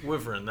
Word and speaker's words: Wyvern: I think I've done a, a smaller Waver, Waverin. I can never pronounce that right either. Um, Wyvern, I think. Wyvern, that's it.